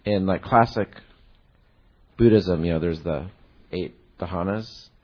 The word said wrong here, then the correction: Jhanas: Am I pronouncing it wrong? Dhanas. Jhanas. – Jhanas? – Jhanas. – Oh, sorry. – Jhanas. – Jhanas. – Oh, okay, Jhanas.